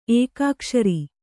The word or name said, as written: ಏಕಾಕ್ಷರ